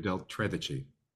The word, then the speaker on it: Del Tredici: David Del Tredici. – Del Tredici.